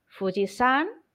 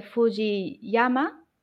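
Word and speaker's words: Fujisan (富士山): Fujisan, que es el monte Fuji, ojo que no se dice Fujiyama, que es incorrecto, que es, a veces se dice, en este caso el kanji de montaña se pronuncia san, ni, partícula ni, porque el verbo aquí es noborimas.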